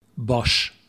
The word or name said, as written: Bosch